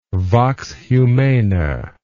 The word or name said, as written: vox humana